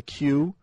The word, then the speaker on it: Que: One's called the Q. – Just the letter or Qui-Wi? – Q-U-E. Ooh. But it's pronounced Q. And Q-U-E-U-E? – No, just Q-U-E.